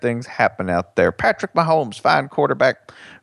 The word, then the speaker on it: Mahomes: And I think Texas Tech, their offense, Patrick Mahomes, I believe it's how you pronounce his name. – That is how Mac Brown would pronounce it. – It's got a little Spanish in it. – Patrick Mahomes.